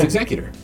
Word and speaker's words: Executor: It's Executor. Because Lost Stars, which I did finish this week, includes a part where they're talking about the Executor, and that's how it's pronounced.